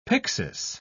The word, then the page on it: pyxis: British English